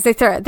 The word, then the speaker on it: Zathura: Yes, Zathura, that's how you pronounce it.